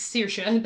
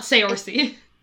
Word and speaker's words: Saoirse: For those of you who gave a shit, it's Saoirse. That's how you say that name. It's not Saoirse.